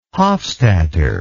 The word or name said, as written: hofstadter